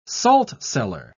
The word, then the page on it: saltcellar: British English